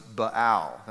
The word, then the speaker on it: Baal: The name of this particular idol that Ahab brought in was Baal. That's how you say it.